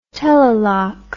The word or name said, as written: telelock